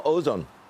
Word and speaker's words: Ozon: Julia, what's the significance of, you know, companies that are being attacked like Wildberries and now Ozon? – Yeah, that's right. So you said it right.